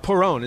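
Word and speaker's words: porrón: Perron, is that my pronouncing it correctly? Mm-hmm.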